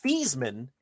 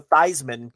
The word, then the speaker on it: Theismann: Did you know Joe Theismann, Rich, pronounced his name Feasman until he got to Notre Dame, and he changed it to Theismann because it rhymed with Heisman, and he thought it would increase his chances of winning the award.